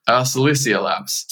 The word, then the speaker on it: Silicea: Is it Silicea Labs? – Silicea Labs. – Silicea yeah. Labs.